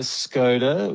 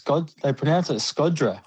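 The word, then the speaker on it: Shkodra: Yeah, and we went to Skoda, which they actually pronounce Skodra.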